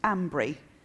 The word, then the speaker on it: Almondbury: I live in a place called Almondbury, which the locals pronounce Ambry.